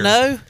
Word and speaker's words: Cano: Well, what was the one canoe? – That was when you were not here. – Cano? Is that what you pronounce it as? Yeah, Robinson Cano.